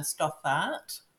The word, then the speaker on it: Stothart: So my website's Fiona Stothart with the TH in the middle,